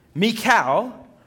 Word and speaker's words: Michal: Michal, her name's pronounced Michal, you'll know that once you go to Israel, it's Michal,